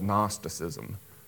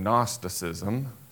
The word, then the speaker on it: Gnosticism: And the Greek word for knowledge is gnosis, so you can hear gnosis in Gnosticism, silent G.